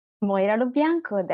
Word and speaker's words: Moira Lo Bianco: Welcome back to The Beats today with Kelly Kennedy and one of my dear honored guests, Moira LeBlanc Bianco. Moira Lo Bianco. Did I say it right? – Moira Lo Bianco. That's perfect.